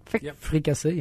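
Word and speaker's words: fricassee: Fricasse? Did I say that right? – Fricasse, yeah.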